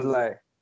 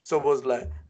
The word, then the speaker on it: Szoboszlai: Szoboszlai. – Szoboszlai. Right. – Yeah. – Thank you. Szoboszlai.